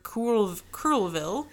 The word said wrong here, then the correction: Courville: It's me not knowing how to pronounce French. It's Courville.